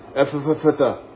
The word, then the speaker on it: Ephphatha: He spit, he touched his tongue, looked up to heaven, sighed, and then he said that funny word, Ephphatha. All right. I don't know if you have a better pronunciation. There's no vowel between the two PH sounds. So you have to sort of like stutter almost. Ephphatha.